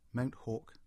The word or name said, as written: Mount Hawke